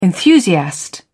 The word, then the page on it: enthusiast: British English